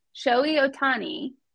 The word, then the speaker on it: Shohei Ohtani: In 2021, MLB Los Angeles Angels pitcher Shohei Ohtani, and I said that right,